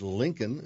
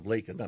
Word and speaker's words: Laken: He mispronounced the name. He said Lincoln instead of Laken.